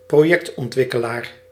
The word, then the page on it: promoteur: [pʁo.mo.tœʁ]; France